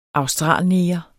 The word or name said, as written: australneger